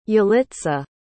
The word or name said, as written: yalitza